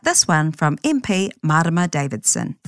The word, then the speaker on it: Marama Davidson: Kia ora, ko Marama Davidson aho.